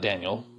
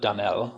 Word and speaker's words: Danel: Next there's Danel, not Daniel, but Danel, without an I.